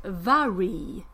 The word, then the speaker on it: Mhairi: Och att hennes namn is pronounced Vary. – Vary? – Inte Mary alltså, som jag trodde.